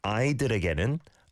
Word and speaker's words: 아이들에게는: Really good, but the 아이들에게는, that kind of 아이들에, was a little different for some reason. I can't quite pinpoint it, but 아이들에게는, 아이들에게는, 아이들에게는. It's many syllables in a row.